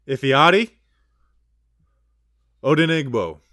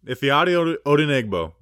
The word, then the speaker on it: Ifeadi Odenigbo: We're going to talk about, and I learned how to pronounce this guy's name. – I'm never going to. – Ifeadi Odenigbo. Ifeadi Odenigbo.